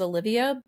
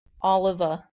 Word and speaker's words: Oliva: And then I've been saying his last name is Olivia, but it's Oliva, or I don't know how to say it.